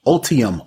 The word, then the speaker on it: Ultium: They're using their new Ultimum. I think that's what it's called. Ultimum Ultium, Ultium, I said it wrong.